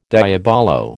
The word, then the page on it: diabolo: American English